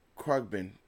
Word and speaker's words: Khruangbin: yes, um, Khruangbin, um, yeah, from Texas.